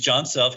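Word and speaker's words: Jon Self: My name is John Self.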